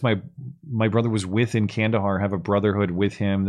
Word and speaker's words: Kandahar: I served around Kandahar. Did I say that right? – Yes, Kandahar, Afghanistan.